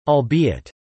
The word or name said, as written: albeit